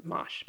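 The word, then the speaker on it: mâche: And for those of you who are new to this, it's M-A-C-H-E. And I actually had to Google how to pronounce it because I kept calling it mache. People sometimes I only had read it. I had never heard someone talk about it. So I was like, I'm planting mache. And then I Googled it and I'm like, oh, it's pronounced mosh.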